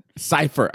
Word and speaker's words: Seiffert: It's a long I, Seiffert. – Seiffert.